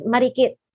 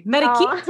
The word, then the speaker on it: Marikit: Oh, it is Marikit. – Oh, you say it in a really beautiful way. Marikit. Oh. There's like a roll there.